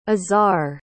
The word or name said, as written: Izarre